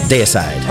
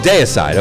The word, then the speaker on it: Deicide: Deicide. – Oh, Deicide.